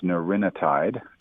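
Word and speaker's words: nerinetide: Well, it's had a number for a long time, just called NA1, but it's pronounced neurinotide, and it's a small molecule.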